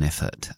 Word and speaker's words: effort: Vale, entonces lo primero es to make an effort, pronunciación de effort, esa palabra, acentuación al principio y schwa al final.